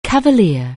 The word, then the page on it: cavalier: anglais